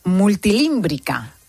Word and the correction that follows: multitímbrica: Multitímbrica. – Sería. Multitímbrica.